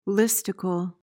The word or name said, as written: listicle